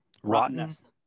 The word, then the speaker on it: Rottnest: Rottnest.